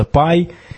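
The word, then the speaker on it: pi: Э, также пекут всякие пироги, но это в частности потому, что по-английски пи произносится пай, и как бы поэтому пай, все пекут, пекут пироги.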